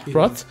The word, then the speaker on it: Roth: Roth.